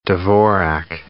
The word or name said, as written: Dvorak